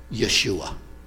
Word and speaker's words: Yeshua: Yeshua. That's the Hebrew pronunciation.